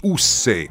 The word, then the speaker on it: Ussé: Cercate le immagini di Castello di Ussé con l'accento sulla E e vedrete che meraviglia è,